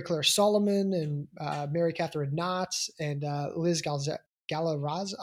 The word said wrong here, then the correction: Galarza: Gosh, I'm sorry, Liz. I know I just butchered that name. Galarza there. I think I got it.